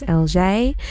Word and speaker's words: ELJE: And it's LJ, E-L-J-E, pronounced LJ.